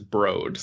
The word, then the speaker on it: Broad: I always thought it was the Broad Museum because that's how it's spelled,